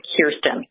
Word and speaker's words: Kirsten: First, I should say that I never take offense because it's not an easy name to say, but it is Kirsten, like your ear.